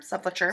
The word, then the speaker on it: sepulchre: Sepulchre? Sepulchre. – Sepulchre. – I said that right the first time.